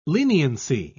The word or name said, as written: leniency